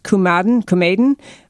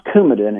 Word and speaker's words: Coumadin: And one that I thought was being called into question about what it signified about her is that she's on lifelong, and I believe it's pronounced Coumadin, C-O-U-M-A-D-I-N. And that's something that I thought that you were reading something into in terms of what that signifies about her health. – Pronounced Coumadin, and Coumadin is a very important medication.